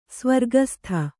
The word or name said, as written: ಸ್ವರ್ಗಸ್ಥೆ